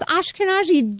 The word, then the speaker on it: Ashkenazi: Well, Dr. Gammon here found out he was Ashkenazi Jew.